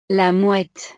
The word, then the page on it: la mouette: frances